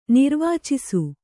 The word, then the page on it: ನಿರ್ವಾಚಿಸು: nirvācisu